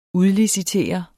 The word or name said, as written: udlicitere